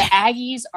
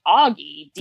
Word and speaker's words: Aggie: Kat, I'm glad you've now been educated on the proper pronunciation and meaning of the term Aggie. While hearing Augie deeply hurt my soul, I remain steadfast in my support of the Free Cat movement.